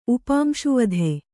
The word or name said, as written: ಉಪಾಂಶುವಧೆ